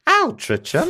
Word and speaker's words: Altrincham: She lived in a specific town of Altrincham would be pronounced differently because it's English. Altrincham.